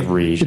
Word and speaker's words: Riege: R-I-E-G-E. It's like Rigge.